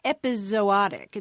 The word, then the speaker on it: epizootic: That's so interesting that your grandmother picked up on that because, you know, at least as early as the mid-1700s, we see this word that's pronounced epizootic. It's the word you're talking about, E-P-I-Z-O-O-T-I-C. Is that the way it was? – Yes, that's it.